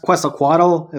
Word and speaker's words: Quetzalcoatl: Yeah, I mean, the Mesoamericans had Quetzalcoatl, if that's how it's pronounced. – That's pretty close, and that's about how I say it.